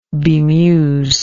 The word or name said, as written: bmews